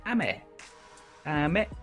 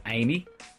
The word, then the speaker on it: ame: So you'd be tempted to pronounce it as Amy, but it's not. It's Japanese, so it's ame, ame.